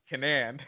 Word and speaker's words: Kanan: Kanan. Like that. – Yes. Right? Yes. You got it. Kanan.